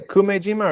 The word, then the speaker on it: Kumejima: And there's also shown forgive me, I don't speak a lick of Japanese, but pronounced Umeshima habu. – Yeah, you're right. Yeah, did you say Kumejima or Umejima? – Kume.